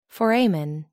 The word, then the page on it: foramen: American English